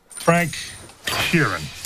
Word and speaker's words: Frank Sheeran: Frank Sheeran. I said that right? – Yeah, you said right.